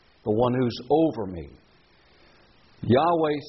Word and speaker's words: Yahweh: Whenever you see the word Lord in all capitals, that stands for God's proper name, which is revealed to us in Scripture to be pronounced something like this, Yahweh.